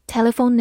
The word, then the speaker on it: telephone: Telephone, T E L E P H O N E, telephone, T E 发 t，L E 里，P H 发 f，字母 O 发它本身的音 o，字母 N 是一个鼻音，末尾的 e 不发音。 telephone，telephone，它是个名词，表示电话，或者动词打电话。比如说，telephone number 就是电话号码。 telephone number。